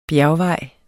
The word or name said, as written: bjergvej